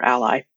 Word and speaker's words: A11Y: The number 11 in the middle of that is the 11 missing letters between the letters A and Y, and it's often pronounced Alley or Ally.